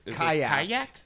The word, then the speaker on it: Qajaq: And how do we say, is this Kayak? – Yeah, Kayak's, but it's spelled Q-A-J-A-Q.